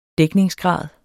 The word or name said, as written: dækningsgrad